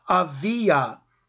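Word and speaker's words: Aviya: Verse 5, in the days of Herod, king of Judea, there was a priest named Zacharias of the division of, I'm going to pronounce it the way it would be pronounced in Hebrew, Avia, but in English we would call it Abijah.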